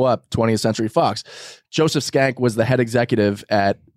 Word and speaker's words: Schenck: It's spelled S-C-H-E-N-C-K. – Oh, my God. – Pronounced Schenck.